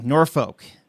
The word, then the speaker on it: Norfolk: Norfolk. Did I say it right? Or how do you say it? – It's Norfolk. – Norfolk. Almost like you're saying the bad word.